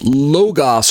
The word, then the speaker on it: Logos: Logos, L-O-G-O-S, pronounced Logos or Logos by other Christians, but I choose to pronounce the Greek word Logos. Because the two letters are omicrons, there's no omega in there. So there's two letter O's in the Greek. There's an A, like the O in doctor, and it's the one that looks like the letter O. That's pronounced A.